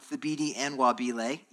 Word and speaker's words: Thabiti Anyabwile: Uh, and to answer that, in that chapter, um, Thabiti Anyabwile, yes, that's his name, and that's how you say it, uh, he gives us four things.